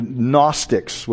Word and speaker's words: Gnostics: We have the idea of, the Greek word is gnosis or gnosko, and that's where we actually developed the word, the Gnostics with the silent G, who were looking for secret knowledge and hidden knowledge.